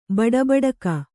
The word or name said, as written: ಬಡಬಡಿಕೆ